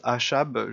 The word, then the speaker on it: Achab: Alors je prononce Achab, je ne sais pas, les Anglais ne prononcent pas Achab, euh, je vais prononcer comme ça, ça s'écrit A-C-H-A-B, en anglais il n'y a pas le C.